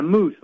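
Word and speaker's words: Moos: Yeah, Moose, like the animal.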